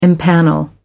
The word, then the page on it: impanel: American English